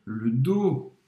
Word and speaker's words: le dos: Je tire le dos. As costas. Le dos, em francês tem um S, mas não se pronuncia, o S é mudo.